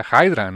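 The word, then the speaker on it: Haedra: Eh, se, lo escriben como e H-A-E-D-R-A, como Hydra, pero pues me imagino que se pronuncia Hydra, ¿no? Como Hydra. como una hidra, exactamente.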